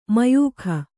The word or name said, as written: ಮಾಯಕ